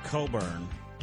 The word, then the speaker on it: Cockburn: A very unfortunate misspelling of Coburn. – What do you mean? – He spells it C-O-C-K-B-U-R-N.